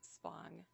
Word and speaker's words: Spong: Yes, Spong, S-P-O-N-G.